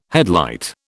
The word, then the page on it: headlight: British English